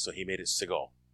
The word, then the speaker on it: Seagal: His name is not pronounced Seagal. – Oh. – It's really pronounced Seagal.